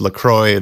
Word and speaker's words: LaCroix: So we had LaQuantroversy, you know, we heard it's supposed to be pronounced LaCroix. The company says it's supposed to rhyme with enjoy.